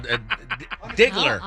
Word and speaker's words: Diggler: That seems like Zingler or something. – Oh, yeah, right. No. Diggler. – How awkward. – I want to say it right.